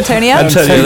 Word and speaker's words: Antonia: Antonia, there you go.